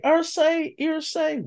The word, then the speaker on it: Irsay: You know his name, Ursa. You gotta stop mispronouncing names. – Okay, Irsay, Wimbaya, Wimbaya.